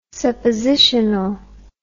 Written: suppositional

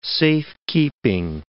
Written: safekeeping

